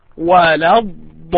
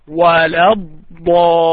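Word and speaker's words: ولا الضالين: This is the time for the extension. So if you make it less than that, so this is not accepted. Let me say it again. وَلَا الضَّالِّينَ وَلَا الضَّالِّينَ.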